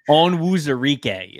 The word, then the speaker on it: Onwuzurike: Onwuzurike is how you say his last name.